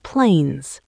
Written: plains

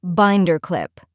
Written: binder clip

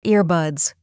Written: earbuds

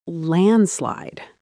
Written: landslide

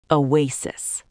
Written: oasis

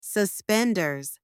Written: suspenders

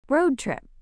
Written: road trip

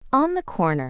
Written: on the corner

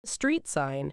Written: street sign

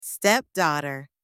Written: stepdaughter

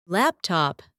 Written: laptop

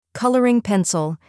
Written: colouring pencil

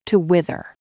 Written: to wither

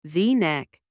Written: v-neck

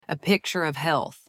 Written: a picture of health

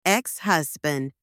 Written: ex-husband

